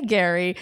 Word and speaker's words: Gerry: This is going to be a bit of a mind thing because it's spelled Jerry, but we say Gary.